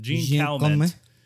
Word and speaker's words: Calment: Okay, it's French. I don't know exactly how to pronounce it. Jean Calment. Jean Calment is how I'm going to say it. – Jean Calment? – In my English-American speech.